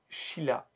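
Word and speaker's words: Chilia (χίλια): Le nom du groupe, directement lié au village qui les a vus naître, 1000, se prononce Chilia en grec.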